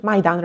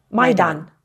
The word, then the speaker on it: Maidan: Am I saying it right? Maidan? – Maidan Revolution, yes. – Say it for me. – Maidan.